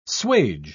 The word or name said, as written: swage